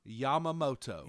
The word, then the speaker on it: Yamamoto: The performance that he had, I feel like I'm going to make you say his name correctly today. – Okay. – Yoshinobu. – Yamamoto. – Nailed it.